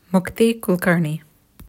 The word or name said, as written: Mukti Kulkarni